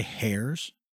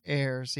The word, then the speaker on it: heirs: Did you just say hairs? – Heirs, yeah. – Heirs. – Hair. – Hair. There is an H there. – There is an H there, but I've never heard anybody pronounce the H.